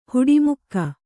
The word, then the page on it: ಹುಟ್ಟಮೂಕ: huṭṭa mūka